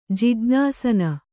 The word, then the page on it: ಜಿಜ್ಞಾಸನ: Kannada